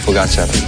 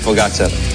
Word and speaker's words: Pogačar: Juanma dice que es aguda, mientras que otros es drújula, así que aquí tenemos cómo se dice de verdad. – Tadej Pogačar. Tadej Pogačar. Tadej Pogačar.